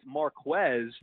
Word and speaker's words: Marquez: Now, did you guys still – you just said Marquez. Marquez is obviously how it's pronounced. The reason why I bring this up, he takes it very seriously. He will not answer questions if you say his name wrong. We say MVS, but it's Marquez.